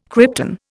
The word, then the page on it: krypton: Indian